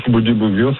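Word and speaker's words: Bundibugyo: Je ne sais pas si je prononce bien, Boudibougyo? – Oui, la souche Boudibougyo, c'est une souche qui avait été auparavant détectée en Ouganda, dans une localité proche de l'enchaînement de la rivière Boudibougyo.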